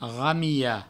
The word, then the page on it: ramilha: provençal; ramiho